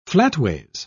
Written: flatways

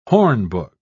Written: hornbook

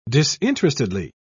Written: disinterestedly

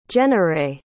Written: Jenarae

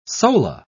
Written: sola

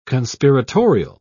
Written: conspiratorial

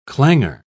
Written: clanger

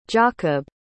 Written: giacob

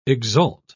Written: exalt

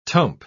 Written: tump

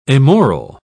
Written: amoral

